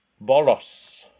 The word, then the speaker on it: Borås: We're at the professional Euro Tour 80 at Borås in Sweden. Did I say that right? – Borås. It's almost like the A is also an O, so it's Borås.